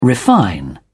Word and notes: refine: British English

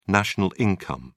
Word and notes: national income: British English

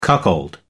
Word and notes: cuckold: American English

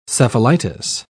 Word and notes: cephalitis: British English

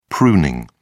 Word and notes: pruning: British English

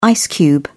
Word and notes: ice cube: British English

